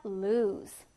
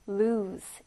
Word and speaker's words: lose: lose. lose. 자, 지금 끝에 발음이 Z 발음으로 끝납니다. lose. 이렇게 발음이 되죠.